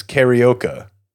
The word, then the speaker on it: karaoke: Last week we did Doncic, and this week I'm learning that karaoke is karaoke, and it's spelled like tapioca.